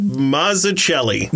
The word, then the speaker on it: Mazzucchelli: Batman Year One is written by Frank Miller and illustrated by David Mazzucchelli.